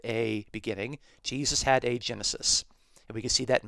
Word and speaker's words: genesis: So the Greek noun genesis, it's pronounced in modern Greek as genesis, means beginning.